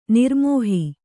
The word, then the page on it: ನಿರ್ಮೋಹೆ: nirmōhe